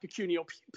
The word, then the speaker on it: Pecunio: I mean, it's actually pronounced pecunio. Pecun means money in Latin, and IO is initial offer, pecunio.